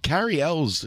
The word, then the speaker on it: Cary Elwes: The Cary Elwes, is that how you say his name? – Yeah.